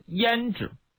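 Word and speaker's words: 阏氏: 匈奴首领的妻子叫胭脂，就跟我们画眉画眼线那胭脂那个读音是一样的。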